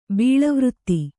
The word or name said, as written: ಬೀಳುವ್ರಿತ್ತಿ